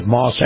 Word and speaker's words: Maus: And my conversation with Chris Markowski is brought to you by our friends at Moss Acura. Moss, of course, is spelled M-A-U-S.